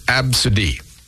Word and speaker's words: ABCDE: How about A, B, C, D, E? Pronounced Absidy.